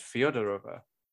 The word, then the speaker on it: Fyodorova: Fyodorova or Fyodorova. I think I said it right. – I think you did. – It's just that it's one of those things that's like Simonova is Simeonova. So this must be just Fyodorova.